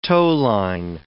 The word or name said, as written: towline